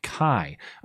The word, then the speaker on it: chi: The letter X is pronounced like Chi.